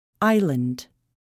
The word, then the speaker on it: island: The letter S is not pronounced before L in some words. Island.